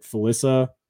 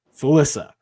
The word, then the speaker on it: Felissa: We've got is it Felissa Rose? Okay, I said it correctly. I said Felicia at one point. You're like, hey, Felissa.